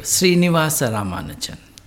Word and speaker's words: Srinivasa Ramanujan: I actually saw a movie recently about a country man of yours called Srinivasa Ramanujan, mathematician. – Yes. – Was that pronounced correctly? – Let me help you. Srinivasa Ramanujan. – Srinivasa? – Ramanujan. – Ramanujan. Yes. It was pretty close.